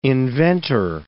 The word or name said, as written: inventor